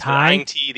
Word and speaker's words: Aing-Tii: I've heard it pronounced two different Ang ways. Aing-Tii, the Aing-Tii, Ang yeah. Ti, Ang Ti, uh, yeah.